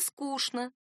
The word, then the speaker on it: скучно: Мне скучно. Поговорим о старине». С тех пор произносительная норма не изменилась. Подстраивать под нее орфографическую тоже не нужно. Написание «скучно» ошибочно. Писать надо с «ч», а произносить с «ш».